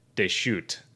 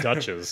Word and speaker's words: Deschutes: And the one, so the, I want to see if I'm pronouncing this right. Here, let's see if this comes through. Here, turn up the audio. – Deschutes. – I was going to say Dutchess, so I'm glad it didn't.